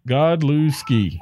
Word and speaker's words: Godlewski: told me how to pronounce his name. Godlewski. James Godlewski, he says it's three syllables.